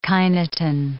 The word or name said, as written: kinetin